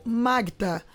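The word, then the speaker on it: Magüta: É um vídeo de 9 minutos que foi produzido pelo pessoal da Secretaria de Cultura do Amazonas contando a história do Museu Magta. Museu se escreve Museu Maguta, mas se escreve Museu Magta.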